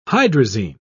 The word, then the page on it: hydrazine: British English